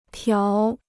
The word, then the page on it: 条: tiáo